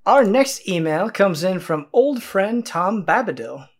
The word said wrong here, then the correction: Bombadil: Oh, Tom Bombadil? – Bombadil.